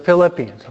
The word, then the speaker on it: Philippians: Second reading, you have the letter of St. Paul to the Filipinos. Sorry, I mispronounced. To the Philippians, okay?